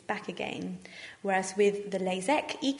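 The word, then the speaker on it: LASEK: First of all is the LASIK, which is spelt with an IK, and the other one sounds exactly the same, but it's spelt with the EK, LASIK.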